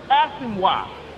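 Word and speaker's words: Asomugha: Was Nnamdi Asamoah. – Asamoah. – Man, that boy was such a just Asamoah.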